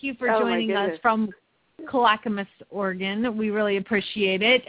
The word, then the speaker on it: Clackamas: Our competitor is Leisha Davis from, she's going to have to help me, Clackamas, Oregon? Lisa, how do you say Clackamas. – That? – Clackamas. Yeah, Clackamas, Oregon.